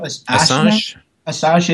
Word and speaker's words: Assange: Assange, yeah.